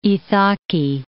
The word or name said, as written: Ithaki